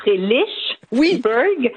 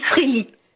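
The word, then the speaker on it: Frelighsburg: Alors, il y a le Frélisberg, oui. – Frélixberg, oui. Et beaucoup disent Fréli, tout simplement.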